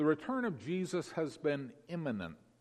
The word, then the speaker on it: immanent: That's spelled E-M-I-N-E-N-T. Eminent, as opposed to imminent. Clear difference in pronunciation. However, there's a trickier word, which as far as I can tell is pronounced exactly the same. And that's imminent, I-M-M-A-N-E-N-T. The only difference is that this word is spelled with an A in the middle rather than an I, pronounced imminent, exactly the same.